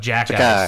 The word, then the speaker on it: Jacquet: There isn't a J in there. No. It's pronounced Jacquet.